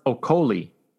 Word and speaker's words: Okolie: Also moving on from the Wembley Arena in London on DAZN, Lawrence Okolie. Did I say that right, Mike? – Yep.